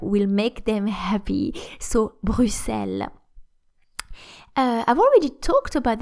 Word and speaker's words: Bruxelles: apparently the the, the, the actually the, the only one true and correct pronunciation is Bruxelles.